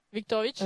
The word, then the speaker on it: Viktorovitch: Viktorovitch.